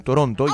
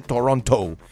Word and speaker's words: Toronto: Yo estamos de viaje, estamos en Canadá, en Toronto. Ya me corrigieron aquí y me dijeron, no se dice Toronto, se dice Toronto.